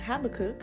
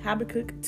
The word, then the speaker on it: Habakkuk: That's a good question, Nia, because the answer is actually given in Habakkuk. Did I say that right? – Mm-hmm. – Habakkuk 2,